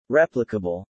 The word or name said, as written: replicable